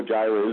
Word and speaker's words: Jireh: Jehovah Jireh, we all know that one from the song, but Jehovah Jireh is the provider. We'll never forget that one. Rhymes with I and provide.